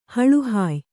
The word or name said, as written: ಹಳುಹಾಯ್